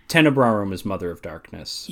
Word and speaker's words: Tenebrarum: There's one, how do you pronounce her name again? Is it Tenebrarum? – Tenebrarum and Lacrimarum.